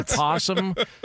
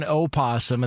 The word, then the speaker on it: opossum: Well, because like some people call it an opossum, and then other people call it a possum. – I've never heard it called an opossum. – Well, it's spelled like opossum.